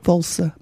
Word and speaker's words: Walser: or rather what Ian imagined the writer Robert Walser might have, that's W-A-L-S-E-R, perhaps not pronouncing it right. – Sounds all right.